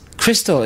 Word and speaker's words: Khrystal: Crystal is spelled K-H-R-Y-S-T-A-L.